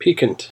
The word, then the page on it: piquant: American English